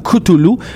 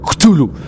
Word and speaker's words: Cthulhu: Souvent, ces noms-là, je vais y revenir, mais en fait, je vais commencer par Cthulhu, qui doit être prononcé comme très aqueux. C'est comme un crachat, Cthulhu. Cthulhu, Cthulhu. T'sais, on a tendance en anglais, d'ailleurs, à prononcer Cthulhu. Cthulhu. – Oui. – Comme en trois syllabes, Cthulhu, mais en fait, ça devrait être deux, selon lui, c'est Cthulhu.